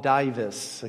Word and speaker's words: Dives: Now, by the way, traditionally this man is called Dives. Okay, that would be close as I can get to the Latin pronunciation.